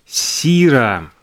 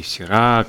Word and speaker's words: Сира: Замечательный Международный день винограда Сира или Сира, кто как ставит ударение.